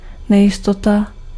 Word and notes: uncertainty: American English; IPA: [ʌn.sɜː.tən.ti]

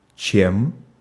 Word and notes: as: American English; [æz]

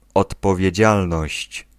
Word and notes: responsabilité: France; [ʁɛs.pɔ̃.sa.bi.li.te]

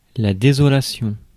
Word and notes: pain: /peɪn/

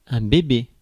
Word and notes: babe: /ˈbeɪb/

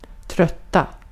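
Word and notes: trötta: Swedish

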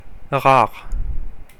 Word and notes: rare: /ʁɑʁ/; [ʁɑʁ]; /ʁaʁ/

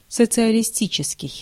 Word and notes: социалистический: /sət͡sɨəlʲɪsʲˈtʲit͡ɕɪskʲɪj/

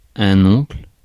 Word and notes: oncle: France; /ɔ̃kl/

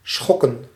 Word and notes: disturb: /dɪsˈtɜː(ɹ)b/

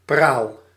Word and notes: pompe: /pɔ̃p/

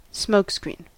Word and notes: smokescreen: /ˈsmoʊk.ˌskɹin/; /ˈsməʊk.ˌskɹiːn/

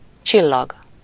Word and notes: csillag: [ʧi.llɒɡ]; /ˈt͡ʃilːɒɡ/

